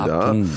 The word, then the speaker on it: 앞동: 앞동이죠, 동. 네, 예, 앞동. 앞동. 네. 예, 된 발음을 하면 안 되죠. 네. 알겠습니다. 앞동, 네.